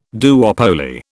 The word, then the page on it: duopoly: British English